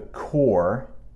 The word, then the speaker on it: Corps: Often called the Corps, yes, I know that's not how it looks, but I promise that P is silent.